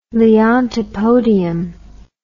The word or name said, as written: Leontopodium